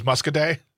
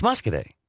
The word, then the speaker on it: Muscoda: What was the town that we thought was Muscaday? – It's Muscoday. – Muscoday. What did we? – It's spelled Muscoda.